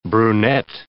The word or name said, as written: brunet